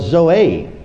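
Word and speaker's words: Zoe (ζωή): This one in these particular verses is spelled Z-O-E, pronounced Zoe.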